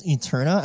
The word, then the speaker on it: Aeterna: So Lux Aeterna, I think the AE symbol is pronounced with an E sound.